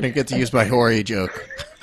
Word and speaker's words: Horry: Okay, so these other three are from Horry County, which is Horry. Usually improperly pronounced Horry, which is... – It's rude. – There could be some areas that are Horry, but it's pronounced Horry.